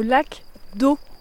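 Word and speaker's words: lac d'Oô: On est au lac d'eau. Ça s'écrit O-O.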